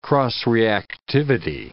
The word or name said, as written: cross-reactivity